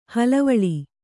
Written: ಹಳವಳ

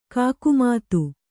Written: ಕಾಕುಮತಿ

